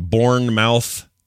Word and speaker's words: Bournemouth: It's spelled like Bourne, like Bourne identity, but the E. – Yeah. Yeah. But you don't pronounce the mouth. You just say Bournemouth.